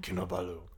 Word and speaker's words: Kinabalu: Kinabalu.